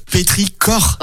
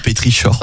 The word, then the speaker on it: pétrichor: Le pétrichor et non pétrichor. – Voilà, ça s'écrit chor, mais ça se prononce cor. – Avec un cas.